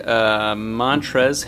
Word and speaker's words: Montrezl: I'll say Montrezl Harrell. Possible sixth man of the year, possible most improved player. – Yep, sure. And definite X factor. I know that L in his first name is silent, but you might as well add a little X on the end.